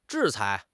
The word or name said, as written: 制裁